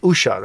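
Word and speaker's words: Uxía: Macarena Gomez, who plays Usia. – Usha. – That's how you say it.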